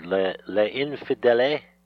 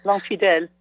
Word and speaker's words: L'Infidèle: So the one I'd like you to read is, I don't even know if I can pronounce it, Le Infidèle? – L'Infidèle.